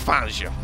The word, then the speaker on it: Fangio: But I think Fangio, did I say that right? – Vic Fangio.